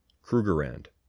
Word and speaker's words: Krugerrand: Krugerrand, not land.